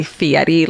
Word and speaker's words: Fieri: Fieri like set. – Oh, you're pronouncing that correctly.